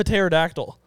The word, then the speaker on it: pterodactyl: Pterodactyls. – I'm trying to find a pterodactyl. – P is silent.